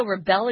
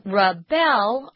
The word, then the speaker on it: rebel: And people who rebel are called rebels. Notice the pronunciation is different. If it's the noun, the accent is on the first syllable, rebel. But the verb is rebel.